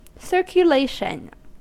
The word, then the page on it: circulation: American English